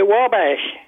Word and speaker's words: Wabash: Wabash or Wabash? – Okay, Wabash. – Wabash. All right, I was close.